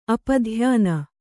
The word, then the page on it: ಅಪಧ್ಯಾನ: apadhyana